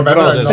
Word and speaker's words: Manetti Bros.: Quindi, no, Bros si legge Brothers.